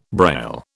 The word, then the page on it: Braille: British English